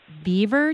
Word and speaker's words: Biewer: And there is this one that I saw in the pictures, and I think you pronounce it Beaver Terrier. It's spelled B-I-E-W-E-R and has this like long straight hair and a ponytail, like a ponytail for a tail.